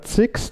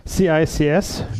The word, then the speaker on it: CICS: Ja, und zwar rede ich heute über, im Deutschen ausgesprochen, CIX, das Customer Information Control System. Im Englischen in der Sprache. Oh. Hallo, ja. CIX oder CICS üblich, aber ich bleibe bei der deutschen Aussprache.